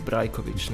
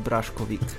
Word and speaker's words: Brajkovič: Ja, Francozi pač ne morejo izgovoriti Brajkovič, ne, in potem izgovarjajo Braškovik, ne, ampak tudi v redu.